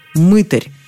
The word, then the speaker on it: мытарь: Обратите внимание на ударение. На «ы» оно приходится только в слове «мытарь». Во всех остальных переходит на «а» – «мытарить», «мытариться», «мытарство».